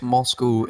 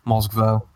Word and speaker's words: Moscow: Moscow in the Russian accent, it does go Moskva. For some reason, but no, it is Moscow.